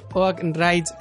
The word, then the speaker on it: Oak Ridge: Bueno, esto lo voy a pronunciar como quiero, pero como siempre hay que pronunciarlo en inglés, ¿vale? Oak Ridge eh,